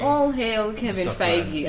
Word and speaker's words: Feige: That's how you say that guy's name, by the way. – Feige. – Yes, it's Feige.